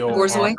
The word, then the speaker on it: Borzoi: It's I think it's Borgioi. – Borzoi? – Bor.